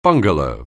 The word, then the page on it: bungalow: anglais